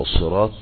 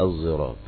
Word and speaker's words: الصراط: وليس الزراط تكتب بالزاي، إنما تكتب بالصاد، كلها مكتوبة بالصاد، لكن حال النطق تقرأ الصراط والثراط بالسين والزراط، وهي تقرأ بإشمام الصاد فيقال الزراط، لكنها مكتوبة بالصاد أيضا وليست زايا، ليست زايا، إنما هذه طريقة النطق فتقرأ بالإشمام.